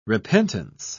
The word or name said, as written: repentance